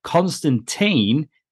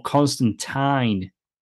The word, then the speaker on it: Constantine: Gaiman online has ended the debate on whether Constantine is pronounced Constantine, T-E-E-N, or Constantine, T-Y-N-E. Gaiman shared the panel from 1985's Swamp Thing 37, in which John Constantine says Tyne, T-I-N-E, writing Alan Moore named and co-created the character, and that's how he pronounced it. So it's Tyne.